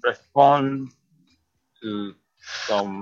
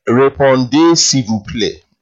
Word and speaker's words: répondez s'il vous plaît: It clearly, you know it, but you didn't pronounce it properly. It's répondez s'il vous plaît, and it translates as "please respond."